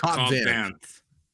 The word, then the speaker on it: Cobb Vanth: Cobb Vanth? How do you pronounce his name? I always forget. – Cobb Vanth.